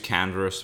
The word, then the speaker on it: Canva: So I use Canvas for a lot of things. I'm sure a lot of other... – Sorry, Canvas? – Canva. – Canva. It's the Australian pronunciation.